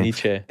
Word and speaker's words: Nietzsche: Yeah, Nietzsche.